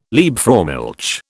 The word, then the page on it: Liebfraumilch: British English